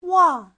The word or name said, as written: wang4